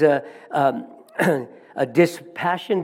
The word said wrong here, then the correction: dissipation: did I say that correctly? Dissipation, thank you.